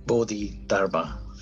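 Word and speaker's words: Bodhidharma: Is it Bodhi Dharma? – Bodhi Dharma, yeah.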